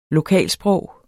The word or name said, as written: lokalsprog